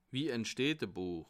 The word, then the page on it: wie entsteht e Buech: Bas Rhin; Haguenau